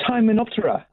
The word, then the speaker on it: Hymenoptera: It's Hymenoptera. – Hymenoptera.